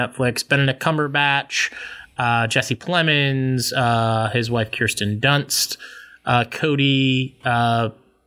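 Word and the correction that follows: Smit-McPhee: I don't know how to say his name. Hold on, I want to get this one right. Cody Smit McPhee he's getting a lot of Oscar buzz for actually a Best Supporting Actor nom.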